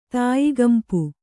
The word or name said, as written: ತಾಯಿಗಂಪು